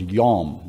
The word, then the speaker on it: yom: It is the word yom. That's how you would pronounce it.